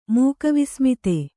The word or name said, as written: ಮೂಕವಿಸ್ಮಿತೆ